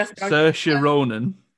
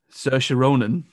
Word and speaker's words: Saoirse Ronan: Maybe Saoirse Ronan. – Saoirse Ronan. Oh, did I say her second name wrong? – You already said sausage for the first bit.